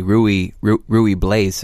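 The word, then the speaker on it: Ruy Blas: I mean, we spent like 5 minutes before we went on air trying to figure out how to pronounce Riblas. So. – It's R-U-Y-B-L-A-S. – Yeah, mm-hmm. – So it could be done. You could try to say Rui Blaise if you wanted to.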